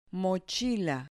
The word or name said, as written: mochila